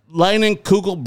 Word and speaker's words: Leinenkugel: Jacob Breinenkugel. – I always say it like Jacob Leinenkugel Brewing Company. Jacob Leinenkugel Brewing Company. Jacob Leinenkugel Brewing Company. – There you go.